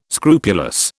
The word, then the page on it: scrupulous: British English